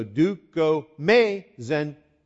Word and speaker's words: Dukomaezinyeh: Dukomaezinyeh. And you have to put the accent on the end or else you change the meaning of the word. Okay? So, Dukomaezinyeh.